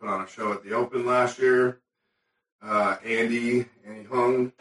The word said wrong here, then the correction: Huang: he's out. Am I saying that right? – Well, Huang. – Okay.